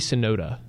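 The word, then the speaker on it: Tsunoda: It's T-S-O-N-U-D-A. – Yoki Sonoda. That's how you say it. I can only say it with his first name. It's Yoki Sonoda. – Yeah. – The T is silent.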